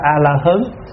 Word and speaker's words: A-la-hán: Cho nên thay vì A-ra-háp đọc là A-la-hán thì các cụ dạy là đọc A-la-hớn.